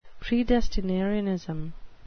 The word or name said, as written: predestinarianism